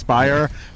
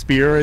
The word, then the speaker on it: Speier: where they had initially had my name mispronounced as Alex Speier. That has since been fixed. It is now Alex Speier in the audiobook.